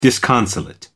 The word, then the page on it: disconsolate: American English